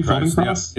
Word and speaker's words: Feldenkrais: But there was a person by the name of, and I'm going to butcher this name, Moshe Feldenkrais? – Maybe? Feldenkrais? Yep. Feldenkrais. – There we go.